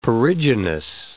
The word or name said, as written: perigynous